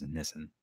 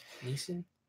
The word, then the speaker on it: Nissan: I know the correct pronunciation is Nissan. Nissan? Nissan. Nissan. It's it's like how we pronounce that one uh, noodle company who makes that ramen, Nissin ramen. Oh, yeah.